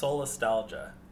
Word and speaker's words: solastalgia: I think it's pronounced solastalgia. – All right. – So like nostalgia, but...